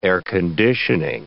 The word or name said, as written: air-conditioning